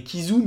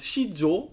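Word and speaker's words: Qi Zhou: Ils ont une très grosse équipe, notamment, voilà, Shizhou, ça doit se prononcer en chinois. En français, on dirait Kizu, mais ayant vécu en Chine, je sais qu'on prononce Shizhou, quelque chose comme ça.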